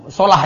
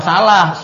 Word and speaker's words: Salah: Bukan salah, solah kalau bacanya. Solah, ikut-ikut berita lagi bacanya salah itu. Solah, Muhammad Solah, itu bener namanya. Kok salah, Muhammad Solah. Bacanya itu Muhammad Solah kalau bahasa Arabnya, bukan Muhammad Salah, saya nggak tahu bahasa Arab aja itu berita-berita.